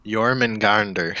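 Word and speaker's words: Jörmungandr: Jormungandr.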